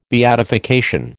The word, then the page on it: beatification: American English